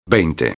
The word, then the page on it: 20: espanhol